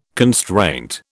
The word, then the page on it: constraint: British English